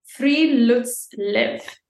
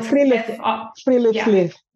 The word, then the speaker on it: friluftsliv: And it's called a friluftsliv. That's spelled friluftsliv.